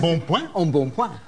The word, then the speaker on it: embonpoint: How do you say that? – Embonpoint.